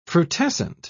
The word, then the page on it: frutescent: British English